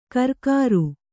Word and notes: ಕರ್ಕಾರು: Kannada

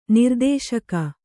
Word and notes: ನಿರ್ದೇಶಕ: nirdēśaka

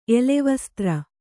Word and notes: ಎಲೆವಸ್ತ್ರ: elevastra

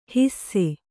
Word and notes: ಹಿಸ್ಸೆ: hisse

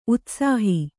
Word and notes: ಉತ್ಸಾಹ: utsāha

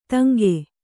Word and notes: ತುಂಗಿ: Kannada

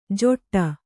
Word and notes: ಜೊಟ್ಟ: joṭṭa

